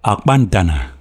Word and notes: Akpantana: LHHLL

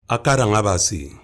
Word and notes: akaranabasi: Efik

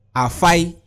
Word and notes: afai: Efik

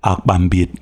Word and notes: Akpambiet: LLLL

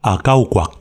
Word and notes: Akaukwak: LHLL

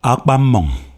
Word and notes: Akpammawng: HHHL

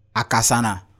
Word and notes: akasana: Efik

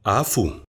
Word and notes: afuu: Efik

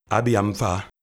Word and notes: Abiamfa: HLHF

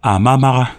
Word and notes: Amamaha: LHHH

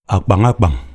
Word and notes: Akpangakpang: LLHL

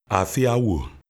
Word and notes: Afia owo: LHHL

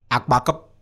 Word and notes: akpakip: Efik

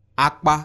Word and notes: akpa: Efik

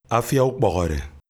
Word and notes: Afia ukpawhawde: HHHLLL

